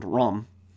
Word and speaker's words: Rum: It's spelled R-U-M, but it's, I think it's pronounced with a much more A sound, Ram.